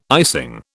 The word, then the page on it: icing: British English